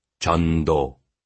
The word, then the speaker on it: Jun Do: "It's Pak Jun Do," Jun Do said. Then he pronounced it slowly. "Jun Do."